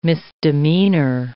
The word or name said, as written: misdemeanor